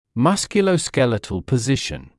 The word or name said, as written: musculoskeletal position